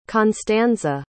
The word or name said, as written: constanza